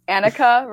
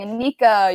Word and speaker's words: Annika: So I've gotten a lot, and I mean a lot, of mispronunciations of my name. And at some point, I just like, stopped caring. I'm like, oh, you can call me Anika, you can call me Annika. I really don't care.